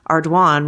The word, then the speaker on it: Ardoin: I live in Lake Charles where Pizzolatto grew up and that is a really common surname, but it's pronounced Ardoin rhymes with swan.